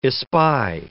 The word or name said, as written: espy